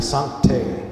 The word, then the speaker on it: sancte: Should be sancte, sancte. So it would be veni, comma, sancte spiritus. So I spelled that wrong. S-A-N-C-T-E, long E, long E.